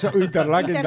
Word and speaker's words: Interlaken: Interlaken también.